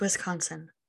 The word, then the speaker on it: Wisconsin: You're saying it wrong. It's Wisconsin.